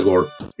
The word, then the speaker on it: gore: sí, sí, en, el, término original en inglés es gore, ¿no? Okay. se pronuncia gore. Eh, la castellanización pues sería gore, también es correcto.